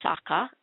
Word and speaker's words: Socha: Michael Sacha, it's pronounced Sacha, S-O-C-H-A, plays the Knave of Hearts.